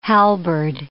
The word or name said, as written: halberd